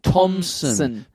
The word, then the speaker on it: Thompson: Sorry, I pronounced the P there. There's no P. – Thompson. – Peter as in P-E-T-A, as in the people for the ethical treatment of animals.